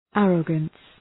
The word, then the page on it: arrogance: ‘ærəgəns